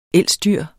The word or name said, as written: elsdyr